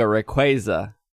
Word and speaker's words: Rayquaza: And from what we know, based on just stat data, prior to this, Mega Rayquaza, is that what it's called? – Yeah. Oh, are you at like pronunciation? – No. Pronunciation is Mega Rayquaza.